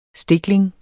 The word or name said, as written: STIKLINGER